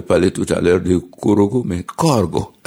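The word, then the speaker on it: Korhogo: Vous avez dit Korogo, et c'est très bien prononcé.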